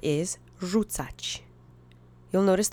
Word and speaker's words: Rzucać: Rzucać means to throw. It's the infinitive of the verb. The accent is on the first syllable.